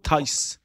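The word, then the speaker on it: Thijs: تیس یا تایس دالینگا هر چی که تلفظ درست اسمش هست تو تایس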